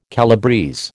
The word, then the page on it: calabrese: American English